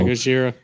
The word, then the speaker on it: Gojira: Gojira, the drum solo. – Yeah, Gojira, yeah.